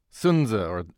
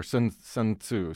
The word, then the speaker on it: Sun Tzu: Sun Tzu or Sun Tzu sometimes pronounced.